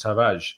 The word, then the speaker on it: Tavares: you don't pronounce the R it's not like a hard R, and so it's just Tavares. From all of the Portuguese people I've spoken to and from hearing him say his own name.